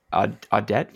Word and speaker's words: Audet: But the family that owns Cogeco, the Audet family? Does anyone know if that's correct, the pronunciation? – Yeah, I believe that's accurate. That sounds right.